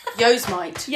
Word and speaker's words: Yosemite: Mate, don't get me started on Yosemite, which I only found out recently was Yosemite.